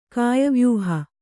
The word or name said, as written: ಕಾಯವ್ಯೂಹ